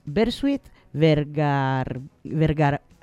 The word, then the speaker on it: Bersuit Vergarabat: Es Bersuit Vergarabat.